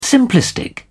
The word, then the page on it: simplistic: British English